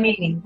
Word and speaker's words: Amini: since this all started happening with Mahsa Amina, was that how I say her name? – Amini, Mahsa Amini.